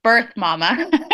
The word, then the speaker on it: Birth Mama: And it's pronounced, my husband gives me a hard time about this all the time. No, because I guess nobody gets it, but it's Birth Mama.